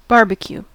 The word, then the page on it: barbecue: American English